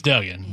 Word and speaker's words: Duggan: Am I saying it right, or is it Dugan? Is it Dugan or Duggan? – I think it's Duggan.